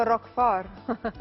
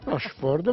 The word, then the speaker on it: Roquefort: Che io ho chiamato Roquefort. – Roquefort, ma va bene uguale. – Grazie. L'ho detto bene? – Secondo me sì.